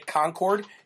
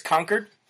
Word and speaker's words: Concord: Just like if you come to New Hampshire, do not say Concord. It's Concord.